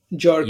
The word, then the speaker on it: Jorge: For, yeah, like, but it's spelled like J-O-R-G-E. J is pronounced ha. – Jorge.